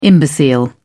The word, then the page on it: imbecile: British English